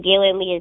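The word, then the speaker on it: Gaelynn Lea: And Galen Lee is spelled GALYNNLEA.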